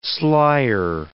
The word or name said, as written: slyer